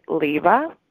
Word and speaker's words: Livva: In the music world, I'm known as Liva, L-I-V-V-A, and thank you so much for having me.